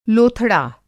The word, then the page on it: लोथड़ा: Hindi